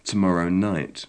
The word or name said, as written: tomorrow night